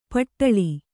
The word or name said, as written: ಪಟ್ಟಳಿ